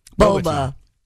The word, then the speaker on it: boba: Is it pronounced Baba or Boba Tea? – Boba.